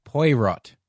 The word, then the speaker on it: Poirot: something like a Poirot or however you pronounce it, or Agatha Christie or whatever. – I believe it's pronounced Poirot.